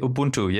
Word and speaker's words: Ubuntu: Yeah, Ubuntu.